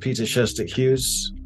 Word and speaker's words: Peter Sjöstedt-Hughes: Well, my name's pronounced Peter Sjöstedt-Hughes.